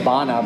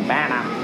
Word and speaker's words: Bana: I think the way you'd correctly pronounce his surname is Bana, but like in Aussie, like Bana. Because that's how we do it.